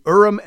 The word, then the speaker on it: Urim: So to go back to your question, the Urim and Thummim, by the way, great job in pronouncing those.